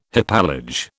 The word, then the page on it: hypallage: British English